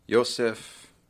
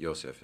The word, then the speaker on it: Josef: Josef Mengele. – Yeah, he was known as the Angel of Death. Mengele. Yeah, and something like that. Josef is how you pronounce his first name.